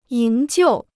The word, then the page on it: 营救: yíng jiù